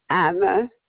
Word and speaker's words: Iva: Ava, I-V-A, Desjardins. – Thank you. – D-E-S-J-A-R-D-I-N-S.